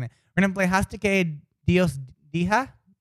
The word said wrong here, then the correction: diga: Which means D-I-G-A. – What does that actually mean exactly? – What is it? D-I-G-A. How do you pronounce the word G-I-G-A? – Diga. – What? – Diga. – Diga. – Hastake Dios Diga? – Yes. Hastake Dios Dija. Diga. There you go.